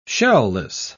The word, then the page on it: shell-less: British English